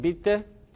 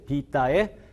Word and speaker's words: vitae: non scholae, sed bibite dicimus. 이렇게 발음하면 교회 발음입니다. 그런데 이거를 non scholae, sed vitae, discimus 라고 발음하면 이게 고전 발음입니다.